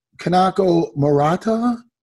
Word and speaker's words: Kanako Murata: No, am I pronouncing it right with Kanako Kanako Murata? Yes. I'm right.